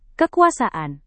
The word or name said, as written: kekuasaan